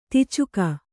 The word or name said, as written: ದುಚ್ಚೌಕ